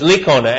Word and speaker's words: Licona: Mike Iacona, is that how you say it? – I-A-C... – Oh, it's Licona, L-I-C-O-N-A. I'm sorry, yeah. It is Licona, yeah.